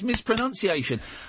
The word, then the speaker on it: mispronunciation: Mis- it's mispronunciation.